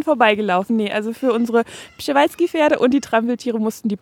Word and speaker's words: Przewalski-Pferde: Ich zähle 6 Tannenbäume für die Trampeltiere und die Przewalski-Pferde. – Genau, die P-Pferde. Schön, dass du den Namen vorher richtig ausgesprochen hast.